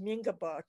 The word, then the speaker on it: Mingaba: It's pronounced Mingaba today. So the way it's pronounced today could be Mien plus Kaba, which sounds like horse world, but it's not.